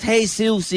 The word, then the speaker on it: Jesus: I think is it pronounced Jesus? Jesus. – It is pronounced Jesus.